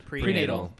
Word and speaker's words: prenatal: It's hard because when I was pregnant, I had perenatal. How do you say that? – Prenatal.